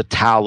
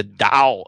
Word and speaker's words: Tao: Some people call it the Tao of Twitter. The Chinese pronunciation is actually the Tao of Twitter. T-A-O is a Chinese word that means path.